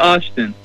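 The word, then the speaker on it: Olsztyn: Yeah, and not only did you play in New Zealand, but you also went off to Poland of all places, and correct me with the pronunciation on this if you like, but Stomel Ulstein, is that right? – Stomel Austin. – Austin, close enough.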